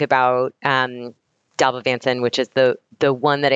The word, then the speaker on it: Dalbavancin: I think Aritavancin and Dalbavancin.